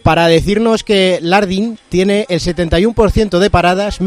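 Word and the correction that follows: Landin: mide 2,01, está sordo de un oído porque tuvo meningitis de pequeño y que se pronuncia Landín, con acento en la I.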